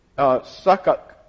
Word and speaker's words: Succoth: Succoth is, you don't pronounce the H.